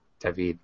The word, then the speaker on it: Dawid: Uh, uh sorry, David. I, I, David, not it's spelled D A W I D.